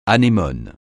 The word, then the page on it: anémone: francais